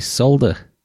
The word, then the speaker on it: solder: That L is silent in the word solder.